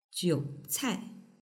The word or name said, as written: jiucai